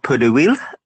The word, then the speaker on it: pot d'huile: Of course, it's called pot d'huile.